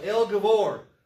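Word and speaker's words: El Gibbor: He is a mighty God, El Gavor in the Hebrew. Spelled with two B's, but it's pronounced with two V's. That's the Hebrews for you.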